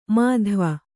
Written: ಮಾಧ್ವ

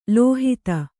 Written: ಲೋಹಿತ

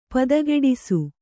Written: ಪದಗೆಡಿಸು

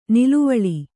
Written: ನುಲುವಾಲೆ

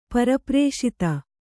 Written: ಪರಪ್ರೇಷಿತ

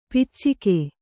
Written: ಪೈಚುಕೆ

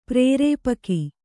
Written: ಪ್ರೇರೇಪಕಿ

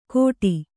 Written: ಕೋಟಿ

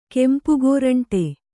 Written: ಕೆಂಪುಗೋರಂಟೆ

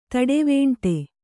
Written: ತಡೆವೇಂಟೆ

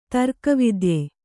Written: ತರ್ಕವಿದ್ಯೆ

